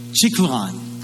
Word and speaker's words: Chilion: And the son's name, the way they pronounce them, is Mahlon and Chilion. All right, that's how they say their name.